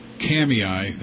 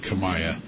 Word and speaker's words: Kamiah: Box 164, Kamiah, that's spelled K-A-M-I-A-H, looks like Kamiah, Kamiah, Idaho, 83536.